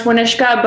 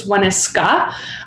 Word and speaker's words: Waanishka: So first of all, in Métis, it's pronounced Waanishka, but in Cree you drop, I think it's the H, it's Waanishka.